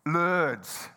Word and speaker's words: Lourdes: And then I realised, or she explained to me, no, not the cricket ground. Lord's. How do I say it? Lourdes. Lourdes. And she goes,